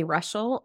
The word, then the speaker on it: Reuschel: You can find me at my name, Emily Reuschel, R-E-U-S-C-H-E-L is how you spell that super fun name that I married into.